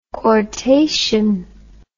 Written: quartation

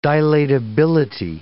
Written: dilatability

